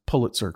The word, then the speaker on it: Pulitzer: Notice I pronounced Pulitzer correctly. Yes. That's how David Lindsay Abaire told me I should be pronouncing it.